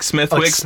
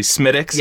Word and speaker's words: Smithwick's: It looks like Smithwick's, like, but it's actually Smittix.